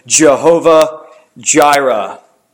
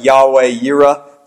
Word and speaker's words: Jehovah Jireh: Abraham called the name of that place Jehovah Jireh. Now, that's the old school pronunciation of it. Some people might want to call it Yahweh Yireh today.